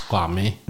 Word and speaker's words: Kwame: Alltså om, om du säger till mig så här, mitt namn uttalas kwami. – Mm. – Jag kommer skratta och säga nej det gör det inte, men jag kommer kalla dig kwami.